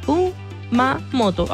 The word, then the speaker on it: Kumamoto: Kumamoto. Ah, sí, lo dije bien. Kumamoto. Ah, lo dije excelente.